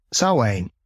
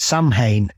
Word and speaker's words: Samhain: something that's come massively into favour over the last sort of 30 years is obviously Samhain. Or as Donald Pleasance famously mispronounced it, Samhain in Halloween.